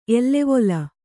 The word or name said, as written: ಎಲವಲ